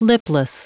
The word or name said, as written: lipless